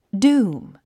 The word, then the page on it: doom: dúːm; ドゥーム